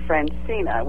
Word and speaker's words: Francina: And they named me Francina, which is spelled F-R-A-N-C-I-N-A from mm-hmm. French. – Yeah, right. – And then Tate, T-A-T-E.